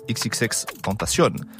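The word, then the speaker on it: XXXTentacion: Obligé de parler d'un artiste au rayonnement mondial, c'est XXX Tentation. C'est comme ça qu'on prononce.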